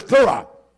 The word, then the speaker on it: thura: It means, it's pronounced as thura. Thura.